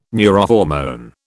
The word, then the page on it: neurohormone: British English